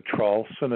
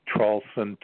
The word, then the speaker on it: Traulsen: Yes, my name is Hugh, H-U-G-H. Last name is Traulson. It's spelled T like Tom, R-A-U-L-S-E-N like Nancy. You can put my name on YouTube or Google it. But if you Google three words, my last name again, Traulson, T-R-A-U-L-S-E-N like Nancy, the word and, A-N-D, and then Trump, T-R-U-M-P.